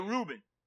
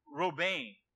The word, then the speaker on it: Reuben: We say Reuben. That's how we pronounce, but it doesn't sound like that in Hebrew. And it's like Robain. It's nowhere near Reuben if we listen to it.